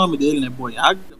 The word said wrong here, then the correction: Bayona: Bayona. – Tipo assim, Isso.